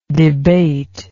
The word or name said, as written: debatingly